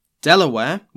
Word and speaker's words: de la Warr: Reinforcements and supplies had arrived, with the governor himself, Thomas West, the 3rd Baron de la Warr. Delaware, which is how I'm going to be pronouncing his name, forced the survivors to turn around.